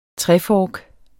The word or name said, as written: trefork